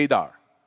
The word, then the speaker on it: Adar: Say Adar. Adar is spelled A-D-A-R.